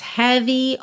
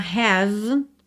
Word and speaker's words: heavy: Então, olha, bears, esse B-E-A-R-S, você pronuncia bears, tá? Bears heavy on my mind. Heavy, palavra que termina aí com Y. Lembra de realmente pronunciar esse som, tá? Cuidado para não falar have, porque senão fica muito parecido com o verbo ter. Então, heavy, tá?